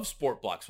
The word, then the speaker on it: SportBLX: Sportblocks, you may be familiar with SportBLX. It's actually pronounced Sportblocks.